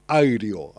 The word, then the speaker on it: REO: Se escribe Reo. Es una banda estadounidense. Y ahí vi que se dice Aireo, algo así. – Aireo Speed Wagon.